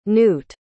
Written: knute

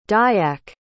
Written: dayak

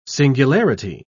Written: singularity

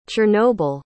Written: chernobyl

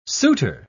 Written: souter

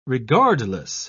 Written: regardless

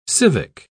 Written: civic

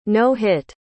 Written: no-hit

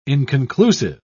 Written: inconclusive

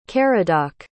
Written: caradoc

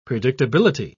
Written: predictability

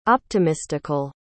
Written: optimistical

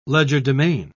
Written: legerdemain

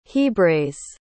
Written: hebraize

